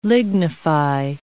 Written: lignify

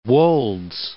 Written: The Wolds

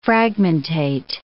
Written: fragmentate

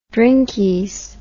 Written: drinkies

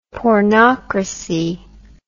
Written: pornocracy

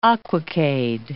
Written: aquacade

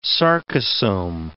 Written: sarcosome

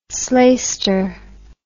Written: slaister

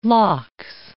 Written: lox

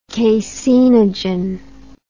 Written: caseinogen

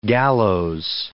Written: gallows

